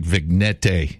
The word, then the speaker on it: vignette: They never say it like it actually should sound. It should sound like vignette. – Yeah. V-I-G-N-E-T-T-E.